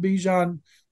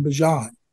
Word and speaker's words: Bijan: watched uh, one of those shows on Bijan, and uh, and I watched his family called him Bijan, mm-hmm. not Bijan. Emphasis on Bijan.